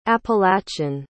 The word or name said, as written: appalachian